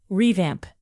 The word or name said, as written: revamp